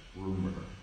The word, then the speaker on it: RMR: And it's RMR, but it's like rumor. That's how you say it.